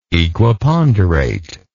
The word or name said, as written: equiponderation